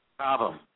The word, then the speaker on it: Cobham: Cobham.